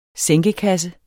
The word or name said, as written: sænkekasse